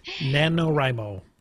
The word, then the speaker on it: NaNoWriMo: November 1st saw the launch of NaNoWriMo. I never say that right. – NaNoWriMo.